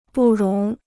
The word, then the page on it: 不容: bù róng